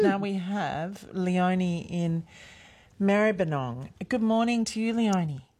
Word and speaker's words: Maribyrnong: Maribyrnong.